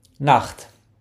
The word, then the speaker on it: Nacht: Nacht. Well done. Now it's time to use these words in a dialogue. Listen for Tag Morgen and Nacht.